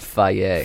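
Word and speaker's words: Fage: It's actually pronounced Faye. – Faye. Yeah, I know. They put it in a commercial. – Yeah, because, you know, it sounds a little bit like a derogatory word.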